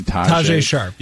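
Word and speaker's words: Tajae Sharpe: Tajay Sharp, yep.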